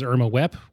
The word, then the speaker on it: Irma Vep: Also, I watched a movie a couple days ago called Irma Vep, which everyone who's French in the movie pronounces Irma Wep, whatever.